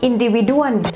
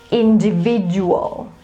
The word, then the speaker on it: individual: I often hear, especially Thai people, that would say something like individuan. Yes, and actually it is not pronounced like that. It is individual. So you stress with the third syllable, which is V, okay? And the last two, you say dual.